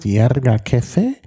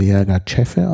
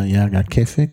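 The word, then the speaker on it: Yirgacheffe: Der heißt Yerga Kefe, von vielen fälschlicherweise Yerga Chefe ausgesprochen, aber Yerga Kefe, Kefe ist nämlich Kaffee. Ist nämlich, weil das mal italienisch war, wird das K mit CH geschrieben.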